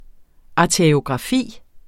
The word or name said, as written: arteriografi